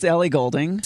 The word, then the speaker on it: Ellie Goulding: I don't even know how to pronounce it because I've never seen this name, but Ellie Goulding? – It's Ellie Goulding.